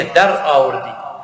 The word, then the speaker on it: الدراوردي: قال عبد العزيز بن محمد الدراوردي، طبعا هي تكتب دراوردي ولكن تنطق بالفارسية دراوردي، يعني دخلت للباء، وكان من أئمة الدنيا.